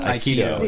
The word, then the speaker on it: Aikido: Aikido.